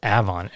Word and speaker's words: Avon: That clip for Avon, and we pronounce it Avon here in the northeastern U.S., it was broadcast on KWEM radio in West Memphis, Arkansas in May of 1955.